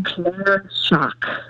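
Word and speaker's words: cláirseach: And, you know, their national symbol, the Irish harp, and I had to look this up, the proper Gaelic pronunciation. Ready for it? – Okay, I'm ready. – Clare Sock. – The Clare Sock, as in put on your socks? Okay. – Like a shock, like an electric shock.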